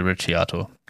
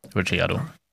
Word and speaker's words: Ricciardo: Genau, wie der Latte Macchiato ist das äh, Daniel Ricciardo. Ricciardo.